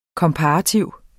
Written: komparativ

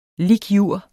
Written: lic.jur.

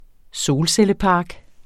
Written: solcellepark

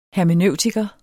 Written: hermeneutiker

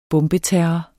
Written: bombeterror

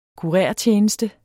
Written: kurertjeneste

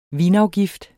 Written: vinafgift

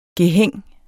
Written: gehæng

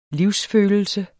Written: livsfølelse

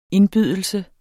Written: indbydelse

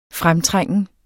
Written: fremtrængen

